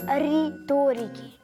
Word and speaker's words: риторики: Ори-торики? Не ори, а просто риторики.